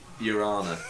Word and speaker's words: Urana: Urana, which sounds a bit like Your Honour.